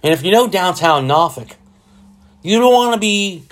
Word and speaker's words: Norfolk: Well, let's just say Norfolk in general. Side note, I say Norfolk because that's how it's pronounced.